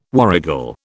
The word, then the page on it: warrigal: British English